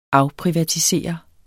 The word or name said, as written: afprivatisere